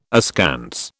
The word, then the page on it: askance: British English